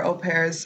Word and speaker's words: au pair: So au pair, for people that like me don't know how to spell it, is spelled like A-U-P-A-I-R, but it's pronounced au pair.